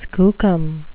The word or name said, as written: skookum